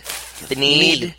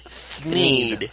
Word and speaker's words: Thneed: Thneed. Thneed.